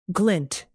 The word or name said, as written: glint